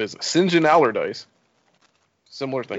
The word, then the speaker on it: St. John: When you see St. John as a name, it's pronounced Sinjin.